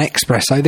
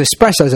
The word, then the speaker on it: espresso: Now, we talked about espresso and expresso. The correct pronunciation of espresso is an age-old argument that coffee enthusiasts have been having for years. But it turns out those who call it an espresso aren't entirely wrong.